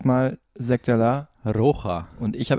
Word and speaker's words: Rocha: Also Rocha. Rocha, also schon mit, Sch, aber langsam ausgesprochen. Ein langsames, langes O.